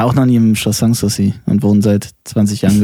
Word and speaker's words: Sanssouci: s a n s und S-O-U-C-I. Sanssouci, ohne Zweifel.